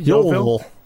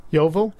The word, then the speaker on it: Yeovil: Yeovil. – Yeovil. – It's like Louisville.